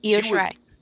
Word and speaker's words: Io Shirai: Io Shirai? Is that how you say it? – Yes. Yeah, Io Shirai.